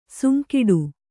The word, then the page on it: ಸುಂಕಿಡು: kannada